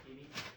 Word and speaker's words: Keady: Robert Keaty, K-E-A-D-Y.